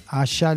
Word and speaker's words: Asa: Una es Asa, Asha le dicen, Asha, se escribe Asa,